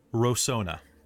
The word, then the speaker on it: Rosohna: he pronounced it horribly, uh, but it is Rosohna.